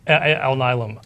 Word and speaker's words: Alnylam: Intuitive Surgical or Alnylam Pharma. Did I say that right? – Alnylam.